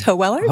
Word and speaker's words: Hoeweler: You're close. It's Hoeller.